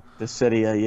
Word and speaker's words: Dissidia: And Dizida? I have no idea. Dissidia? – Dissidia, yeah. That's how you pronounce it. Dissidia.